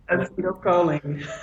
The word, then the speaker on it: acetylcholine: So you, acetylcholine.